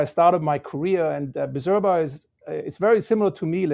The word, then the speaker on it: Bizerba: How did you make the leap from Birzeba, and I'm sure I'm pronouncing it wrong, to Miele. – So the exact pronunciation is Bizerba, Bizerba.